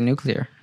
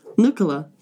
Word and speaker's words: nuclear: I think I'd say nuclear. – Nuclear. – Nuclear. – Uh-huh. – Like new nuclear. Clear. Mm-hmm. Uh-huh. Okay. Yeah, me too. That's exactly. So we're, we're saying it right. yeah, we're saying it right. So the, the incorrect pronunciation is Nukula. Nukula. Nukula. – Is how you're not supposed to say it.